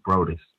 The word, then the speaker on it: Broadus: I pronounced his name incorrectly. DJ Brodus II. – Brodus, B-R-O-D-U-S? – B-R-O-A-D-U-S.